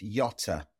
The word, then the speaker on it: Yotta: No, apparently it's a short O. It's YOTA.